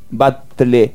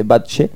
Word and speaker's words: Batlle: Juan Batlle, se escribe B larga A-T-L-L-E. Batlle sería, pero se dice Batlle.